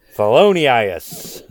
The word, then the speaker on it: Thelonious: Thelonious. All right. – There we go. – It's pronounced like felonious. – It's not, though.